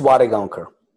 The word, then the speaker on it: Wadegaonkar: It's Wadegaonkar.